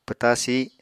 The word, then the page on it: pwta sih: Apatani